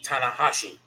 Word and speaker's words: Tanahashi: will go up against Hiroshi Tanahashi.